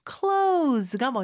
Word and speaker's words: close: 그래서 감다라는 표현인 close가 먼저 오겠네요. 자, 근데 여기서 close의 발음 이따가 하겠지만 중요합니다. Z 발음으로 해주셔야 돼요. Why don't you close?